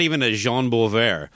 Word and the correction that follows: Bouvier: It's probably Bouvier. – Oh, probably. – But thanks to Kiss fans everywhere, it's now Bouvier.